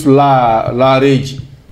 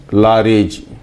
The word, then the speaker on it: Laareejy: In Fulani, we call those spirits Lareji. – Say it again. – Lareji. – How you spell it? – L A A R E E J Y. Lareji.